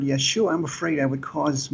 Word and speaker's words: Yeshua: his name was Joshua, and the Hebrew language doesn't pronounce the J, so it would be Yeshua.